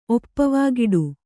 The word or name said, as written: ಒಪ್ಪವಾಗಿಡು